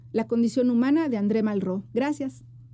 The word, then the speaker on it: Malraux: André Malraux, se escribe Malraux, pero se pronuncia Malraux.